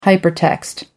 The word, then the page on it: hypertext: American English